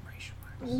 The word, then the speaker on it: Reichsmarks: Reichsmarks.